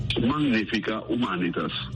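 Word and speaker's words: Magnifica Humanitas: En latín se pronuncia Magnífica Humanitas.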